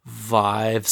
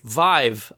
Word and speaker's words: Vive: So Oculus, Vive. So Real quick, I just Googled it. HTC clarifies it rhymes with five. – Okay, Vive. All right, yep. Not Vive.